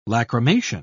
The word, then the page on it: lachrymation: British English